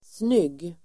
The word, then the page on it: snygg: [snyg:]